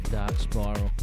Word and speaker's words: DXRK Spiral: pronounce it Dark Spiral, but DXRK Spiral.